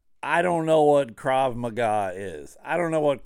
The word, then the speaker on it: Krav Maga: Now, I know it's pronounced Krav Maga, but it's Maga, M-A-G-A.